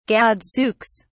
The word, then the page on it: gadzooks: British English